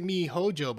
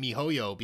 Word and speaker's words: miHoYo: If I want to, my biggest fear, that's one of my biggest fears for me, you pronounced it miHoJo, but I always pronounced it miHoYo because of the Y, but either way, so I'm not really sure which one, to be honest,